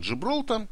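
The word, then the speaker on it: Gibraltar: Ну, те, кто еще не понял, на самом деле так произносится Гибралтар. Хотя пишется он по-английски действительно вот так, Гибралтар, но произносится он Джибралтар.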